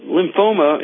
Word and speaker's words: lymphoma: Lymphata, lymphoma. – No, thoma. Lymphoma. – Oh, lymphoma. – Yes.